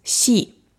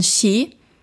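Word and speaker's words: Xi (し): Vielleicht kennst du Yon, das ist die japanische Aussprache, und die an das Chinesische angelehnte Aussprache ist Xi. Und Xi ist die gleiche Aussprache wie Tod.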